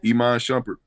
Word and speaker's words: Iman Shumpert: Because Teyana Taylor was, you know, they getting a divorce with Iman Shumpert. What, how you say his name? – Iman Shumpert.